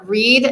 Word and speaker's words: Rhead: You can also find me in both Instagram and Facebook social medias under Carol Reed. And unfortunately, my name is not the easiest to spell, so beware. There's two R's in there, and then Reed is spelled R-H-E-A-D.